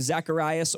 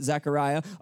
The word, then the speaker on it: Zacharias: Verse 5, "In the days of Herod, king of Judea, there was a priest named Zacharias," or you can pronounce it Zachariah, "of the division of Abijah.